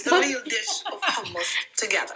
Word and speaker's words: hummus: So it's hummus, very easy. This lesson is finished now. Hummus, it's not hummus, it's not hummus, and it sounds a lot like the Homs city in Syria, but it's not the same pronunciation. Again, it's hummus, you have to double it.